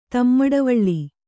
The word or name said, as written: ತಮ್ಮಡವಳ್ಳಿ